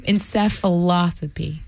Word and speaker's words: encephalopathy: Encephalopathy? Encephalopathy.